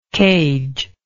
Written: cagelike

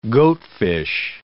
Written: goatfish

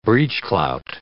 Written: breechclout

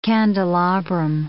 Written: candelabrum

